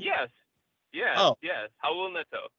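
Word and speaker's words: Neto: And then Raul Nito, who is going to kind of be the – is it Nato? I already messed up the pronunciation, didn't I? – I love it. It's my favorite thing about you. So it is technically Raul Nito.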